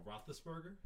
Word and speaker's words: Roethlisberger: Can I get the correct pronunciation of that? – A Roethlisberger? – Yeah, Roethlisberger.